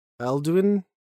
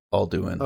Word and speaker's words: Alduin: An epic quest to defeat Alduin? I don't know. The World Eater? – It's pronounced Alduin.